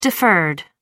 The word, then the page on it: deferred: American English